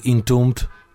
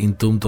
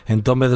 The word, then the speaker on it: Entombed: Son los suecos Entombed, según la pronunciación oficial, Entombed. Pero prácticamente yo creo que nadie en el estado dice Entombed, todo el mundo dice Entombed.